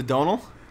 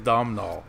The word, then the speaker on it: Domhnall: The Donal? – No, the Domhnall. – Oh, the Domhnall. – Yeah. – Don't you say his name wrong. Don't you disrespect Domhnall. – That's the correct way to say it is Domhnall.